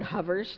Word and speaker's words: hover: I just got this block, and I finally said to Dale, give me some words that sounds like the way I'm supposed to say hover, and he goes, cover, lover.